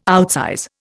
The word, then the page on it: outsize: Indian